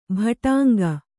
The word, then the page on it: ಭಟಾಂಗ: Kannada